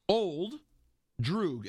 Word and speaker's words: Old Droog: Tell him how to say his name. – Ural Droog. – Your yeah. – Old Droog. It means your old friend.